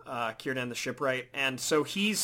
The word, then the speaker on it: Círdan: And what about Sidran? I'm probably saying it wrong. – Oh, Círdan? – Oh, Círdan, sorry. Círdan.